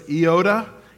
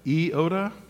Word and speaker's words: Iota: The next verse says, I entreat, and when you look at this, it looks like Yodia, but it's not. It's actually pronounced more like the Star Wars character Yoda. It's Iota, Iota.